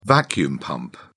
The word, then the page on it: vacuum pump: British English